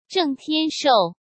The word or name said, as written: Zheng Tianshou